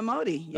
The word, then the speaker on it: Emordi: Yes, Kareemah Emordi.